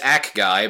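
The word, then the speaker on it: Acguy: This is the point where I realize it's pronounced Ak Guy because Char pronounces it that way.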